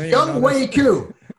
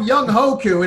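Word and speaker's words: Young Hoo Koo: Where do you get Young Wei from H-O-E? It's Young Wei Koo. I used to call him Young Hoku, and I'm like, ooh, that doesn't sound good. It's Young Wei Koo. Okay, how the hell do you get Wei from H-O-E?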